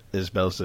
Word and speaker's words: IsBell: It's maybe all of the different Jacob IsBells that exist everywhere in other universes. Yes. So probably my first question is, am I pronouncing your name correctly? – That's it. Just two syllables. IsBell.